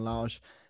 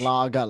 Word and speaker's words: Lage: Obviously, new coach this year, Bruno Lage. I think I pronounced that correctly. I thought it was Lager last week until I was